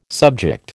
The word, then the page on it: subject: American English